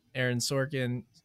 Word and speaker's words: Aaron Sorkin: Anti-peeve, though, I had on the flip side of the coin, thinking of Aaron Sorkin.